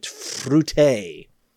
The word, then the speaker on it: Fruité: Fruité. Have you ever had a big plastic jug of that? The word fruit and an E with a little accent on the end of it. Fruité.